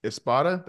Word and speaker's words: Espada: I was like, I YouTubed him against Espada.